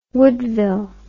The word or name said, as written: woodville